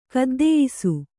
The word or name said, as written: ಕದ್ದೆಯಿಸು